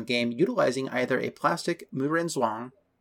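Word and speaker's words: Muren Zhuang (木人桩): Muren Zhuang. More of a Zhuang. J sound.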